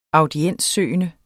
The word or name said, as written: audienssøgende